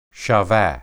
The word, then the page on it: shaba: Hebrew